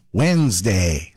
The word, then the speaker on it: Wednesday: It's midweek Wednesday, which of course we pronounce Wednesday. So why are we spelling it with a random D that nobody invited to the conversation? English is full of words like that. Take Colonel, for example. It's spelled C-O-L-O-N-E-L, but pronounced Colonel.